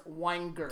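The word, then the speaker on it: Weinger: It's W-E-I-N-G-E-R, but it's spelled, or it's pronounced Wanger.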